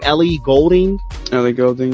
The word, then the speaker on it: Ellie Goulding: Ellie Goulding, yeah. – Goulding.